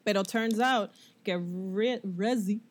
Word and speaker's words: Reze: Y Denji en este otro arc conoce a esta nena que se llama Reese Reze. Se escribe R-E-Z-E.